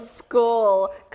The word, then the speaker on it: school: 여기도 크 소리가 나요. 봐봐요. 이렇게 단어를 썼는데 What's missing? 뭐가 빠졌어요? – Oh, 네, 잘했고요. One more. 흐 소리가 나는 알파벳 H였죠? Very good. 학교, school.